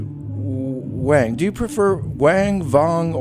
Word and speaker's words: Wang: I only ask because my father's father was Norwegian, and his name was W-A-N-G, which means something totally different in Norwegian. – Absolutely, yes. – Yeah, and they pronounced it Vong.